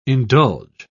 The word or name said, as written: indulge